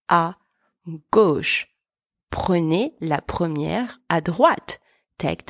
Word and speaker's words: à droite: There is a difference between tout droit, which means straight ahead, that we used in our first expression, where the final T is silent, and à droite, where you hear the T. It's not silent. Tout droit, à droite. Tout droit, T silent, à droite, T pronounced.